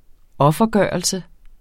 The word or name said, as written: offergørelse